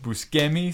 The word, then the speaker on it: Buscemi: Buscemi, sorry. That's apparently how you pronounce it.